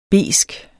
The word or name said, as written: besk